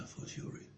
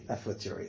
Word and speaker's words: a fortiori: A fortiori. – Sorry? – A fortiori. – A fortiori.